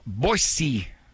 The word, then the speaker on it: Boise: All right, Kurt from Boise, Idaho.